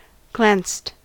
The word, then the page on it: glanced: American English